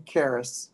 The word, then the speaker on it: Caras: My name is Dean Karas, C-A-R-A-S.